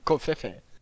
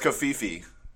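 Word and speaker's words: covfefe: Is that how it's pronounced? Kofefe? – No, it's kafifi. Yeah. No, you're pronouncing it the official Middle Eastern way, where it's actually a word.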